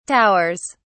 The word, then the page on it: towers: British English